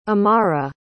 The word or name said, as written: amara